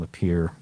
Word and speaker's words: Lapierre: but I fictitiously named the bus driver Kevin Lapierre because it rhymes with deer.